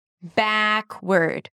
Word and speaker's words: backward: And drop your jaw a lot. Ba. Backward.